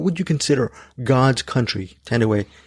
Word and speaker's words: Thandiwe: And then we're also doing a movie with Tandaway Newton, not Tandy Newton. She pronounces her name now.